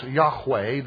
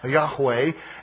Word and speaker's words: Yahweh: That is, in English, we would say YHWH. That would be pronounced Yahweh. There's a guttural sound there, Yahweh.